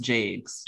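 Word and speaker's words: Jacques: It's not Jakes. It's Jaques. – Yeah, it's pronounced Jakes.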